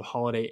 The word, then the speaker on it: Holladay: That's spelled Holladay, H-O-L-L-A-D-A-Y.